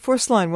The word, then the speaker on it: Forsline: You can just call me Phil, and my last name is pronounced Forslin. – Forslin, I'm sorry. – Yeah, it's actually a Swedish name.